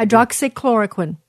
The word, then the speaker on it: Hydroxychloroquine: Hydroxychloroquine.